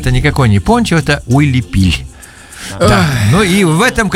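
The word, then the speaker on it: huipil: Правильно говорить, вот Уилипиль, да.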